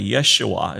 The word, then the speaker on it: Yeshua: Yeshua is how it would be pronounced as Joshua in the Old Testament.